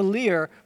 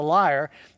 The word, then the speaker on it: lyre: It started out after that time, he was invited to come into the, palace, to play the lyre for the king. Now, some translations say harp, but the word that's used there in the original Hebrew means the lyre. Lyre or lyre is pronounced both ways.